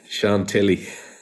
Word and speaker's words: Chantilly: Chantilly.